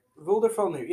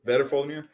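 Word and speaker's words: Veðrfölnir: A lot of the things say it doesn't have a name, but it is referenced, and it's pronounced Vedrfolnir. – Yeah. – Vedrfolnir.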